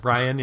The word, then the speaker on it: Rian: That's not how he spelled Ryan, or however you pronounce it. – R-I-A-N, Ryan, yeah.